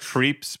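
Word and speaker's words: Krieps: I think the last name is probably pronounced Creeps, but it's hard to tell. It's K-R-I-E-P-S.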